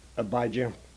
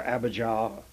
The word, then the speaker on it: Abijah: And in our English pronunciations, we put the emphasis on the first three letters, A-B-I, and less emphasis on the last. I was trying to turn that around, but I realize that I'm going against established pronunciations and well-known pronunciations. So whether you say Abijah or Abijah, it doesn't make much difference, and I will not press the question.